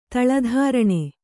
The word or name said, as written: ತಳಧಾರಣೆ